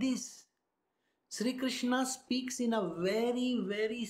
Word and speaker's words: Krishna: Krishna. Krishna. Emphasis is not there at all. Krishna. Krish. Krr. Did you get it?